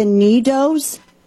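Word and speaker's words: NeeDohs: They were buying, and I guess I'm saying this right, the Needos. – Is that on this? – N-E-E-D-O-H.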